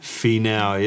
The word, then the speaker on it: Finau: Finau, yep. – Oh, okay. – And I is an E sound, so Fee, and then AU is Al, especially for, and I think he's Samoan, right? – I think so, yeah.